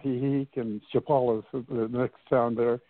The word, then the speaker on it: Chapala: Lake Chappas. Is that right? How do you say that? – Lake Chapala. Lake Chapala.